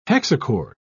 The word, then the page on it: hexachord: British English